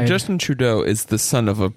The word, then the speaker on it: Justin Trudeau: Justin Trudeau.